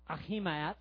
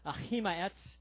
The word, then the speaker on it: Ahimaaz: 2 Samuel 18, verse 19 reads, "Now Ahimaaz, son of Zadok," I researched these pronunciations, okay? "Ahimaaz,